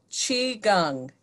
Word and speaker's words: Qigong: So we're gonna do about 45 minutes together of an exercise called Qigong. It's pronounced Qi as in C-H-E-E, Gung, G-U-N-G. That's how I would write it phonetically. And the way we write it in English is Q-I-G-O-N-G.